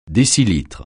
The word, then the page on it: décilitre: French